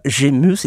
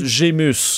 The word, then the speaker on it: Gémus: C'est Nicolas Gémus. Il est G, e, accent aigu, M-U-S. – L'album s'appelle. Je Gému, là, mais là, dis plus Gémus.